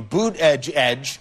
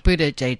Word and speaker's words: Buttigieg: All right, so you pronounce it boot, B-O-O-T, edge, edge. – Buttigieg.